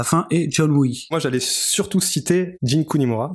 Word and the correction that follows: Jun Kunimura: Jun, Jun Kunimura.